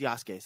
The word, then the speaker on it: Yasuke: Oh yeah, I like Sasuke. The Sasuke or Yasuke? – It's Yasuke, sorry.